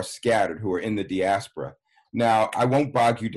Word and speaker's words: diaspora: So in your book, you used a phrase that I was not familiar with, and I might mispronounce it. Diaspora, is that proper? – Oh, diaspora.